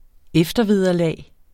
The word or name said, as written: eftervederlag